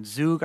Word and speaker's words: Zug: in zug i think it's pronounced yeah exactly Zug, yeah that's where i'm currently starting my business there so i'm registering my business currently in Zug.